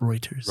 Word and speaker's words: Reuters: But it's pronounced Reuters.